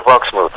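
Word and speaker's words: Wachsmuth: How do you pronounce his surname? Is it Waschmuth? – Yeah. – Steiner's assistant, Waschmuth. Was it? – Gunther Waschmuth. – That's it, yeah.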